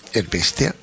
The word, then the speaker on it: Erbestea: En este disco, El Bestia, lo he dicho bien ahora, ¿no? – Cojonudo.